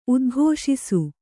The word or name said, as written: ಉದ್ಘೋಷಿಸು